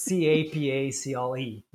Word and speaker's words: Capacle: But think of it as capable, but with a C instead of a B. So it's Capacle. That's my last name. So at Capacle.